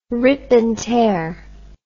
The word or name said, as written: rip and tear